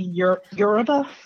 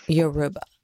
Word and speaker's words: Yoruba: So what is the correct pronunciation of that West African religion? It's called Yoruba. – Yoruba.